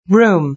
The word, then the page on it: room: British English